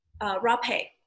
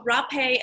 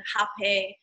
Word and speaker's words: rapé: So I myself administer rapé. So rapé, and some people, depending on what culture you're from, there's different pronunciation, hapé. For me, it's rapé. It's spelled rape with like an accent above the E.